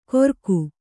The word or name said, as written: ಗೊರಕು